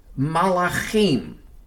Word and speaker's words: malachim: The usual Hebrew word for angels is malachim. The transliteration in English is spelled M-A-L-A-C-H-I-M, malachim.